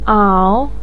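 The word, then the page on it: ao3: tone 3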